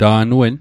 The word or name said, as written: ta'n wen